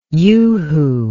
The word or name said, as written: yoohoo